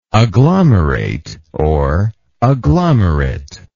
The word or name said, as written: agglomerator